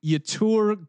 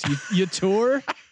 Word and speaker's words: Yetur: But I think Yetur Gross Matos, Yetur, I just pronounce it like neuter, but Yetur Gross Matos, he kind of fits that Seattle defense.